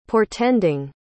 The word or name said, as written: portending